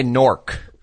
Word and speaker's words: Newark: Here we just say Nork. N-O-R-K, Nork. That's how we pronounce it. – It was W-N-R-K here, an AM radio station. – W-N-R-K.